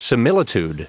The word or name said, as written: similitude